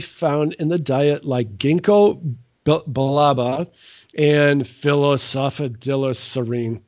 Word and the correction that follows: phosphatidylserine: It's phosphatidylserine. – Phosphatidylserine. – Yes, I think you said it right.